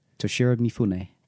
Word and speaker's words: Toshiro Mifune: Toshiro Mifune.